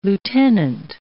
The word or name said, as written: lieutenant